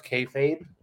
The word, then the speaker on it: kayfabe: So just to correct you, it's pronounced kayfabe. Kayfabe.